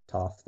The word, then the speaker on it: Toph: That's before they meet Toph. Toph, that's how it's pronounced.